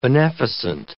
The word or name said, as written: beneficent